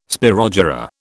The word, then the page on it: spirogyra: British English